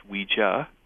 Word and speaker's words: Ouija: Well, originally, it was trademarked and pronounced Ouija, but by the 1920s, people were saying that when they played the board, they were Ouija-ing. So either or.